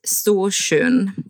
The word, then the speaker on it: Storsjön: Was ich während meiner Recherchen immer wieder gelesen habe, von einem Ungeheuer von Störsjön, falls ich das richtig ausgesprochen habe. Worum geht's da? – Grob gesagt ist das unser Loch Ness, unser Nessie, genau. Und der See heißt Storsjön, das heißt übersetzt großer See.